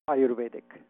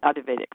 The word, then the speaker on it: Ayurvedic: Is it Vedic or Vedic? – Ayurvedic. – Ayurvedic, that's what I thought.